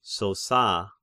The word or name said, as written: Sosa